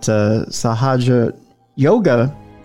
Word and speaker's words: Sahaja Yoga: And we were talking about Sahaja Yoga. Am I saying that right, by the way, Val, or am I not? – Yes, yes, okay. You're saying it fine.